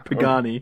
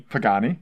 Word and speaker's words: Pagani: Pagani. – Pagani.